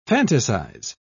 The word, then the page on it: fantasise: British English